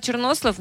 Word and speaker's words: чорнослив: Але чорнослив, ну, марин. – Чорнослив – це дуже смачно. – Мінус вайб. – Дуже смачно. – Мені до сих пір цей наголос мені ріже вуха більше, ніж та кісточка, яка в ньому потрапить.